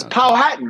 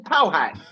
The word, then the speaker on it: Powhatan: Yeah, so Powhatan. I'm trying to figure out how to properly pronounce it. That's where I'm at right now. – It's Powhatan, man. You got Manhattan and Powhatan.